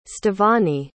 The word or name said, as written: stevany